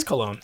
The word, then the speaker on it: Cologne: Is it Cologne or Colonia? Cologne, right? – It's Cologne. – Cologne, thank you. – Well, technically in German it's Köln, but we in the American.